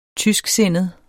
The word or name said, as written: tysksindet